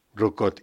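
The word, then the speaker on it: Roucaute: No sé decir el nombre de los autores, Denis Jenbar, Yves Rocaut, ¿cómo se dice esto? – Rocaut. – Yves Rocaut. – Denis Jenbar. Pienso que la pronunciación es esa, ya que son franceses los dos.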